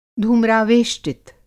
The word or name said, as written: धूम्रावेष्टित